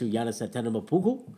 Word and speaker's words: Giannis Antetokounmpo: And shout-outs to Giannis Antetokounmpo for winning the Kobe Bryant.